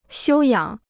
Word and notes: 休养: xiū yǎng

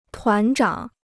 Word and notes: 团长: tuán zhǎng

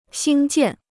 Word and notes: 兴建: xīng jiàn

